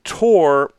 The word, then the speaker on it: tour: You might have got me on composting and composting, but tour is defensible. That is a defensible pronunciation because it rhymes with poor and four, and I've never heard anyone say fewer.